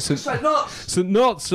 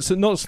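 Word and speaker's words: St Neots: How do you say it? – St. Knots. – We're at St. Knots tonight.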